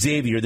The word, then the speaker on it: Xavier: Please, for the love of all that's good and wholesome, Xavier is pronounced Xavier. There is no X in the pronunciation.